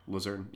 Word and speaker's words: Luzerne: How do you say it? – Luzerne. Yeah, Luzerne County.